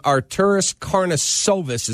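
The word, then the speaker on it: Arturas Karnišovas: The new GM, a guy named Arturis Karnisovas is how I pronounce it. We'll find out if he ends up, you know, agreeing to this deal. He'll tell us how to pronounce it, but that's how I've been told it is.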